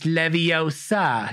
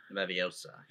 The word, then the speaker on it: Leviosa: It's Leviosa. Yeah. – Leviosa.